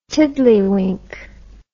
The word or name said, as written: tiddlywink